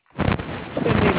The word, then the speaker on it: Cindy: Cindy Long.